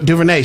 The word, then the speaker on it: DuVernay: But the flip side to it is Ava DuVernay, I don't know if I'm saying her name correctly, but she was on. – DuVernay? – Yeah, Ava DuVernay.